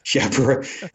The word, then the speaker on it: Chapra: How often have people tried to, speaking of your surname, try to pronounce it Shapiro, like, say, Robert Shapiro? – Shapiro, Chapra, Shepherd. – Chapra. – It's butchered. It's Chapra. It's butchered, and it's just to be expected at this point.